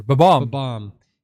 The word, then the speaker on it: Bob-omb: It sounded like he was saying bomb, but with a stutter. Bob-omb.